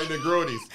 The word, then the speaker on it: Negroni: So he has the Negroni first. Did I say that right? – Mm-hmm. Negroni, yeah.